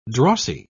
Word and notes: drossy: British English

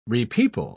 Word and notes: repeople: British English

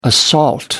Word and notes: assault: American English

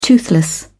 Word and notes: toothless: British English

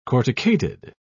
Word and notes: corticated: British English

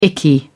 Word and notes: icky: British English

